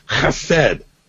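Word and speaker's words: Chesed: So the heart of Hesed, the word is spelled C-H-E-S-E-D. I wasn't raised in Israel. I don't know how to speak Hebrew. So bear with me. But the word is Hesed, and it means loyalty.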